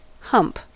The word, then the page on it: hump: American English